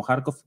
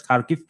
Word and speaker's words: Kharkiv: de esta ciudad que en español lo conocemos como Kharkov, creo que usted, corrígame, creo que acabo de escuchar bien la pronunciación, es Kharkiv.